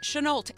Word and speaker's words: Chenault: Paige Chenault.